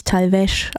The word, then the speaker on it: Talvez: Natürlich Talvez ausgesprochen, nicht Talvez, wie ich es eben anmoderiert habe.